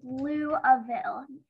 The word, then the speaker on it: Louisville: But Derek replied, "That's not how you pronounce it. It's Louisville. The S is silent."